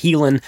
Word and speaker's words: Haelan: I'm going to put a link in the description so you can get to it from there, but it's pronounced Helan 951.